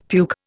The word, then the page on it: puke: Indian